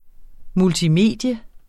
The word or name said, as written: multimedie